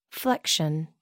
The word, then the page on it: flexion: American English